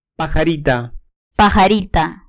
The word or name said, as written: pajarita